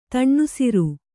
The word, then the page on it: ತಣ್ಣುಸಿರು: taṇṇusiru